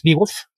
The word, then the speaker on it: Beowulf: Entonces, por eso sé que no, la pronunciación de Beowulf tiene que ser un poquito más extraña. Es de esos casos en los que las vocales las solían ligar. Es Beowulf, pero sí. Es eso de pronunciar exagerado.